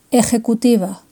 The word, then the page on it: ejecutiva: Spain